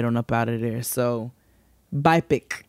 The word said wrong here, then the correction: BIPOC: Is that what you said? BIPIC. That's how you say it. – I don't think that I think it's an acronym. – So you're supposed to just really just sit here every time and say BIPOC.